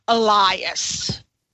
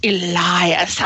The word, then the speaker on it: Elias: Is it Elias or Elias? – It's Elias. – Elias. I like that. – Or in Lebanon, in Lebanon, where we're from, it's pronounced Elias.